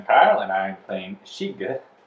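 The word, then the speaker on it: Skygge: But we're going to spell it anyways. It's S-K-Y-G-G-E, and none of you will be pronouncing it right, I guarantee. – It's Shiga. I stopped trying to pronounce it.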